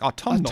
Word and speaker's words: autumnal: We do pronounce the N in autumn when we add a suffix to it, like autumnal.